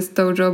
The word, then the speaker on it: Stojo: Myślę, że teraz wielu z Was już szokuje, że kubki Stojo wymawia się Stojo, bo to nie jest polska firma.